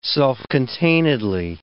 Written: self-containedly

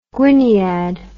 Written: gwyniad

